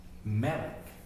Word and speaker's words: Melech: But it was the same with this word Melech, or MLK, which, if it means king, is pronounced Melech.